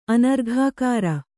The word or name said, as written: ಅನರ್ಘಾಕಾರ